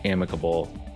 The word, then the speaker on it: amicable: Okay, Merriam-Webster only lists one pronunciation, amicable.